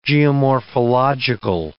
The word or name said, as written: geomorphological